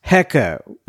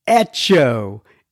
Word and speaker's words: Heco: Apparently, the word H-E-C-O is not pronounced hecko. It looks like it is pronounced echo in Tejas, not Hecko in Tejas.